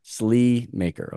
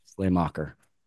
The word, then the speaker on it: Sleamaker: Sleamaker. Okay. See, I would have butchered that one. I would have butchered that one. I was thinking Slay. Yeah. Slay Mocker.